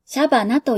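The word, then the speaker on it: 謝花: シャバナさん、感謝の謝に花と書いてシャバナと読みます。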